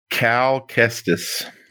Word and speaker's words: Cal Kestis: How do you say this guy's name? – Cal Kestis.